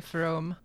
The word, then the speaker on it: Fromm: I'm gonna break with the pack and say it's a, a long O and say from. Uh, according to our pronunciation guide, it's uh, F R. It's pronounced more like F R O O M.